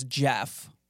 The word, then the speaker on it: Gef: And it says that its name is Jeff, spelled with a G, G-E-F.